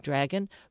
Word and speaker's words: Dragen: It sounds like Dragon, but no, it's spelled Dragen, the Dragon chip.